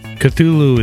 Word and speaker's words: Cthulhu: To be fair, though, H.P. Lovecraft never actually explained how to pronounce Cthulhu. Cthulhu is the common pronunciation for the creature that he created.